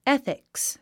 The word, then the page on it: ethics: éθiks; エスィクス